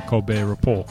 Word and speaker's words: Colbert Report: No, I think it is because it's a silent T for Colbert, and so it's a joke that it's the Colbert Report.